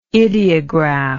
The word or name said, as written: idiograph